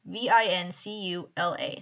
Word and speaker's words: Vincula: I'm sorry if I pronounce that wrong. It's Vincula. So Vincula. Where Queen Anne was buried, he saw a light coming from the building, from the windows.